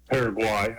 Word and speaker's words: Paraguay: They struggled against Paraguay, I believe is the way it's pronounced. – Oh, I'm sorry. Paraguay.